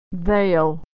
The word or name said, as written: they'll